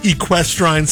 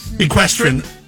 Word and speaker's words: equestrian: They're celebrating their 30th anniversary of being a sanctuary for the equestrine section. – Equestrian.